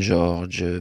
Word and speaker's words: George: Madame Susan George, merci de nous recevoir au Quai d'Orsay. – Vous pouvez le prononcer à la française parce que c'est George.